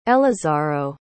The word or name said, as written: elazaro